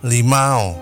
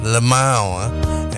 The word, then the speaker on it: Lamau: Some people pronounce it as Limau. But it's Lamau.